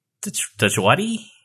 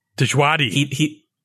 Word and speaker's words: Djawadi: Ramin Djawadi? – Djawadi.